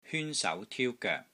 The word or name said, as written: HYUN1 SAU2 TIU1 GOEK3